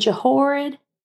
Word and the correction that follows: Jehoiada: Huh? The, Jehoiada? – Jehoiada. Jehoiada. Okay.